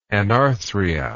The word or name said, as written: anarthric